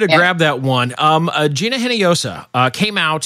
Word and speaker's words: Hinojosa: The H is silent. – Hinojosa. Hinojosa. Gina. – Hinojosa.